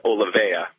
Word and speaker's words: Olivea: And the opportunity to open what's pronounced Olivea, it's Latin for olive.